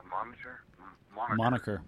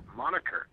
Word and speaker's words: moniker: You know, I think I'm done with the geek monitor. Moniker. Moniker. – Thank you. – There you go, Flynnstress. – It wasn't her. – Thanks, Java, for saying it right.